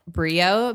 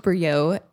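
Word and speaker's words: Brillault: Yeah, I get a lot of that. But it's pronounced Brio.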